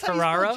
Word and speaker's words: Ferraro: Geraldine Ferrari, right? – That's Ferraro? – Geraldine. Ferraro. – Yeah. Yeah. Yeah, Ferraro.